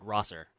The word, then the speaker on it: Rosser: You have Kenta versus Fred Rozier, a.k.a. Darren Young. – Fred Rosser. – Rosser?